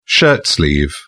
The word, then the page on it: shirtsleeve: British English